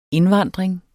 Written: indvandring

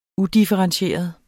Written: udifferentieret

